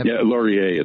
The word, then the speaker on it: Laurier: Laurier, it's pronounced. – Laurier. – It's French pronunciation.